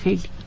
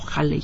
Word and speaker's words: Halley: el Halley o Halley, como lo quiera pronunciar.